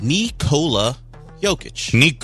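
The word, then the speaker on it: Nikola Jokić: Apparently, it's Nikola Jokic. – Nikola Jokic. We need to pronounce it correctly.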